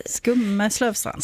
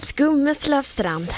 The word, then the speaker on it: Skummelövstrand: Och nu uttalar det fel också, va? – Vi har övat på det. Skummelövstrand. – Skummelövstrand.